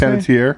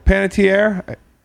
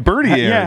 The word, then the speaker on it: Panettiere: Sorry, but Hayden, how do I say her last Panettiere. – Name? Panettiere. – See, that's why I was saying Bertier.